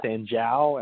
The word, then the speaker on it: Sanjiao: You also talk about the Sanjiao, if I'm saying that right. J-I-A-O, yep. – Sanjiao.